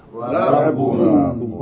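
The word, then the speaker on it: Rabbun (رب): two syllables, shadda on the ba. The first ba is joined to the ra with fatta, rab, and then the second ba with tanween, bun, rabbun. Shadda on the ba, rabbun, rabbun.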